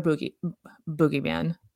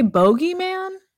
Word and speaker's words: boogeyman: Some people say, I've heard people say bogeyman. – Oh my. – Yeah. And I'm like, have I been saying it wrong the whole time? – The whole time? No, I don't think so. Yeah. Okay. I just say boogie. – Perfect. – Like boogie down.